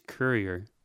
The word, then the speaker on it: Courier: Anyway, the Saline Cooyer. That's how I'm going to say it. I think the correct pronunciation is Courier.